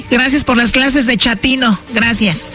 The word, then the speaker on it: chatino: Chapino. – ¿Chapino? – No, chatino. – Es como chato, ¿verdad? Chatino. – Sí, asunero como chato, chatino.